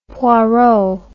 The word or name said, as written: Poirot